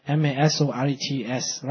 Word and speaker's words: Masoretes: There's a group of Jews known as Masoretists. I hope I spell it, I mean, I pronounce it right. Masoretes, right?